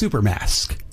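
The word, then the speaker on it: Xupermask: It's spelled with an X, but it's Supermask. Supermask. But it's spelled with an X.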